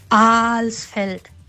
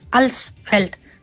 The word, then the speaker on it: Alsfeld: Wenn im Verkehrsfunk zum Beispiel unser wunderschönes Alsfeld, ne, da sagen die Leute immer Alsfeld, krieg spontan Plack. Das kann ich überhaupt nicht leiden, das heißt Alsfeld, nicht Alsfeld.